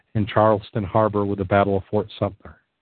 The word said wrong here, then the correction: Sumter: Sumter. I added an extra syllable in there, I think.